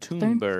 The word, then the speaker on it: Thunberg: Thunberg, I believe, is the proper pronunciation.